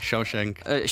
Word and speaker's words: Shawshank: The Shawshank, si pronuncia così? – Sì, Shawshank.